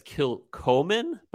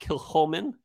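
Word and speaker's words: Kilchoman: And so we are drinking Kilholman Senig, which is, you might see it and read it as Kilcoman, but it's pronounced Kilholman. And it's spelled S-A-N-A-I-G is the release.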